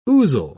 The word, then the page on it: ouzel: British English